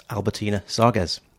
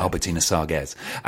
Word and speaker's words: Albertine Sarges: I've got Albertina Sarges. – Albertina Sarges. – Yeah, I heard her say it when she supported Cilla Donnelly. So okay. – I know how to pronounce it. – Albertina Sarges.